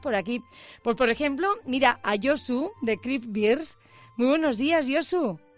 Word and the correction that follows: Craft Beers: Dime si lo he dicho bien y cuéntanos qué es lo que vendéis. – Somos Craft Beers.